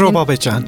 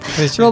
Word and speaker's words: Roba Behcan: Roba Behcan. – Roba Behcan, tabii telaffuz hatam oluyor benim böyle bilmediğim için. Becan. Roba Behcan.